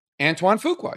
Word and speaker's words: Antoine Fuqua: Is that how you say it? – Antoine Fuqua, yes.